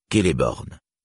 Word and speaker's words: Celeborn: C équivaut toujours à K, jamais à S. Par conséquent, Celeborn se prononce Celeborn et non Celeborn.